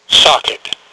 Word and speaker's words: SOCITT: Socket. You know, like on that. – Socket. Old, I like that. Like socket to me, socket to me, socket to me.